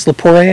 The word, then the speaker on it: Lepore: No, this is Jill Lepore, I think it's Lepore, I think is how she pronounces her name, her Secret History of Wonder Woman.